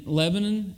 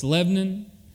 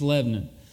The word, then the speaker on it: Lebanon: I pastor a church in Lebanon. Not Lebanon. It's Lebanon. It's just one syllable. And you say it fast and you don't pronounce the O. It's just Lebanon.